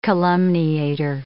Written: calumniator

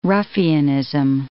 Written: ruffianism

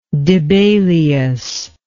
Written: Dibelius

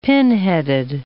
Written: pinheaded

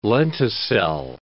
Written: lenticel

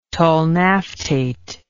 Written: tolnaftate

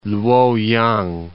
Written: Luoyang

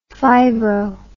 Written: fibro

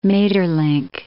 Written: Maeterlinck

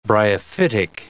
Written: bryophytic

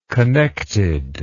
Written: connectedness

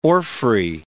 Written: orphrey

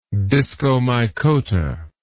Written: discomycota

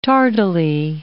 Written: tardily

